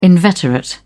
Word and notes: inveterate: British English

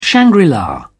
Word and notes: shangri-la: British English

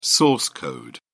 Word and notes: source code: British English

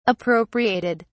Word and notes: appropriated: British English

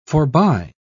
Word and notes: forbye: British English

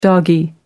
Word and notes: doggie: British English

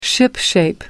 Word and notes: shipshape: American English

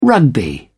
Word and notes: rugby: British English

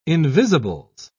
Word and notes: invisibles: British English

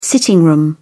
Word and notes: sitting room: British English

